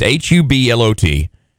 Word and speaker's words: Hublot: And help me with this, a Hublot watch? – Oh, I've seen that at the very fancy malls, but I have no idea how to pronounce it. It's Hublot.